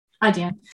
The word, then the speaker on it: Adyen: Is it Adyen? – Adyen.